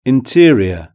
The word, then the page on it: interior: British English